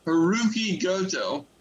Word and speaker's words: Hiroki Goto: So Hiroshi Tanahashi and I'm sorry, I can't pronounce his name. Hiroki Goto. – Yeah. – Okay, good. So I didn't slaughter it.